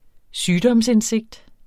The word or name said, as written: sygdomsindsigt